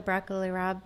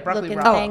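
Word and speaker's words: broccoli rabe: What's it called? Rapini? How do you say that? – Is that the broccoli rob? – Yeah, broccoli rob. – Oh, okay. – Yeah, mm-hmm. Broccoli rob.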